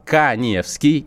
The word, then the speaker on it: Каневский: То есть, если у вас сейчас возник вопрос, что нужно есть, чтобы похудеть, подпишитесь на мой Инстаграм, Эдуард Каневский, Инстаграм, э, моя фамилия пишется через А, Инстаграм у меня с галочкой, подпишитесь, напишите мне в Директ э, пароль шпаргалка по питанию.